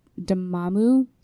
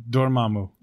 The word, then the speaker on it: Dormammu: He also did the motion capture for Dormammu. – Come on, try it again. – Is that how you pronounce it? – There's an R in there. – There is? – Yeah, Dormammu.